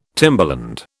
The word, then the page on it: timberland: British English